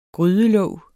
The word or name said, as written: grydelåg